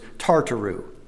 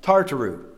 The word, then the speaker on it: Tartaroo: The third Greek word is Tartaru. I'll spell it as T-A-R-T-A-R-O-O. Kind of like, almost like tartar sauce, but not quite. Tartaru.